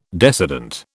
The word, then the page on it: decedent: British English